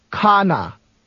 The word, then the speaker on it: खाना: In to eat, the stress is on the beginning. – Khana.